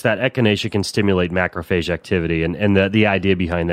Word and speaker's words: macrophage: And it has the ability to, from what I understand, and this is going to be in your wheelhouse, it activates macrophage. Is that how you pronounce that activity? – Yeah, macrophage is an immune system component, yeah.